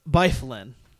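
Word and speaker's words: Byfuglien: He also gave me a pronunciation for the one I kept saying was like Flygen, Flygen. Like, could not pronounce the name. And all right, I'm already accepting my fate. But I have to look at his name Justin. – Again. Yes, and it's Byflin.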